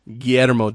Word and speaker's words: Guillermo: Guillermo Del Toro.